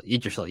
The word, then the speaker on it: Yggdrasil: How do you pronounce it? – Yggdrasil? – Yggdrasil, yes.